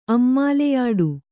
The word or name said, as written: ಅಮ್ಮಾಲೆಯಾಡು